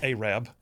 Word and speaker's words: Arab: It's spelled Arab. But it's pronounced Arab.